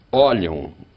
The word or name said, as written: olham